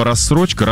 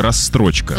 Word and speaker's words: рассрочка: Вместо слова «рассрочка» — «расстрочка».